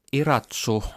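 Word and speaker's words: Irazú: Tässä ohjelmassa ollaan siis professori Jussi Pakkasvirran valokuvien äärellä ja Kolmas valokuva on tuollainen vuoristomaisema, tulivuori Iratsu, niin kuin se lausutaan, ja se sijaitsee Kostarikassa, ja olet siellä ä, äh, tyttäriesi kanssa ä, äh, kävelyllä.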